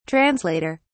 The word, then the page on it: translater: British English